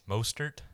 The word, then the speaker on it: Mostert: Mozart. How do you say his name? – Mostert. – Mostert. Why did I say Mozart? – Mozart.